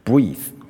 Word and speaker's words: breath: 即便后面多了个一，即便尾一是不发的，但是它是有两个发音，它就是一个破音字。 Breathe 就是动词，breath 就是名词。